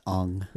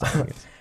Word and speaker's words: Aang: It's pronounced Ong. – It's pronounced Ong.